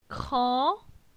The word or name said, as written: Cran